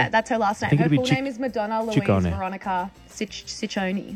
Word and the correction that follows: Ciccone: Well, in Italian, it works the other way. So the double C's are hard C's and the singular C's are soft C's. – So Ciccione. – Ciccone.